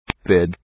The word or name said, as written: big